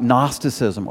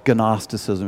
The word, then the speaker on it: Gnosticism: 1 John explains about Gnosticism, or Gnosticism if you pronounce the G.